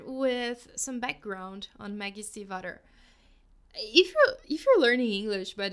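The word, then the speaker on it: Stiefvater: According to her own website, it's pronounced like Steve, like the name Steve, and Otter, like the animal. So Steve Otter, Steve Otter.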